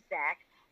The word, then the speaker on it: Xaq: His name is Zach, but it is spelled XAQ.